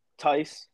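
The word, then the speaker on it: Theis: Is it Thies? Thies, how do you pronounce his name? – Thies. – Okay, yeah.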